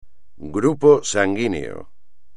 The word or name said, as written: grupo sanguíneo